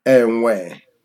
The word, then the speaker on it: enwe: And then with that, was I pronouncing my NW? – You are. You're pronouncing it well. Yep. Enwe.